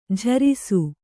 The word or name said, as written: ಜೇರೈಸು